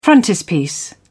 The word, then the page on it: frontispiece: British English